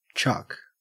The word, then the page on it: chuck: Australian English